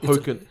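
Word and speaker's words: Joaquin: Hoken? What? – It's Hoken? A Silent J. Hoken Phoenix, right? – Yeah.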